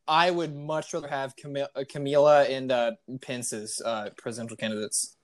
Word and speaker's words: Kamala: No, it's Kamala.